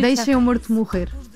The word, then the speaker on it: Deixa Meu Morto Morrer: In Portuguese, it's very hard to pronounce Portuguese, but it's almost Portuguese. – No, you Deixa Meu Morto Morrer. We eat the vowels, so it's very hard.